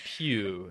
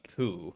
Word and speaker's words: Pew: It is Pew, not Poo.